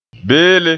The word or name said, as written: beele